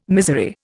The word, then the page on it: misery: Indian